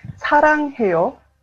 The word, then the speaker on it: 사랑해요: Eh, se dice saranjeo. A ver, repitamos juntas mejor. No, Excelente. – Tenés que intentar, Matías. – Para, de vuelta, ¿cómo se dice? – Harangeo.